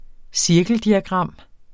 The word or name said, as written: cirkeldiagram